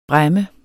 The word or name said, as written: bræmme